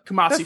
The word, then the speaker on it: Kumasi: Kumasi. I'm pretty sure that's how you pronounce it. The Kumasi Fort.